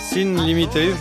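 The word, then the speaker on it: Sin Límite: Sin Limité, je l'ai prononcé correctement, Alain? – Perfecto.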